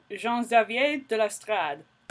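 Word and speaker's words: Jean-Xavier de Lestrade: It's kind of less about, to me, is he guilty or innocent, and more about, like, kind of the director, who's a, I can't ever say his name, I think it's Jean-Xavier de l'Estrade, which is quite a name. – Jean-Xavier de l'Estrade. – Oh, there we go.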